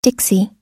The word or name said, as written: dixie